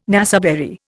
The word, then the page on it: naseberry: Indian